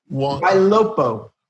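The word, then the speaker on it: Ylopo: Yalu, how do you pronounce it? Why Lopo.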